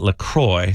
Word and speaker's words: LaCroix: Officially, they pronounce it LaCroix, so it rhymes with enjoy, not LaCroix or LaCroix. Yeah, I think French might say it like LaCroix.